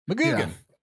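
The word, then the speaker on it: McGuigan: McGugan. Yeah. – I missed that second G. McGugan.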